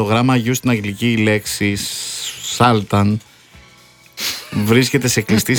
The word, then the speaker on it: Sultans: Οι Άγγλοι όμως άλλαξαν την προφορά της όταν την ενέταξαν στη δική τους γλώσσα. – Μάλιστα. – Επειδή οι Dire Straits είναι βρετανικό συγκρότημα και τραγουδούν στα αγγλικά, προφέρουν τον τίτλο ως Sultans of Swing.